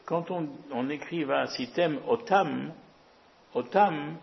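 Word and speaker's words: Otam (אותם): Donc ça se lit quand même Otam, mais ça s'écrit de telle sorte qu'on puisse le lire aussi, et ce serait la première lecture, si vous avez Aleph, Tav, Mem, vous allez lire ça à Tem. Pas Otam, mais à Tem.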